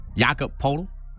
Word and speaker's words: Poeltl: The San Antonio Spurs have traded Kawhi Leonard and Danny Green to the Toronto Raptors for DeMar DeRozan and Jakob Poeltl, or Poeltl. Am I saying that right? Poeltl, right? Poeltl. – Yep, Jakob Poeltl.